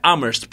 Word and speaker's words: Amherst: Mas eles são de uma cidade que eu não consigo pronunciar o nome, que é Amherst. Amherst. Eu acho que é Amherst. Ah, é Amherst, porque é A-M-H-E-R-S-T.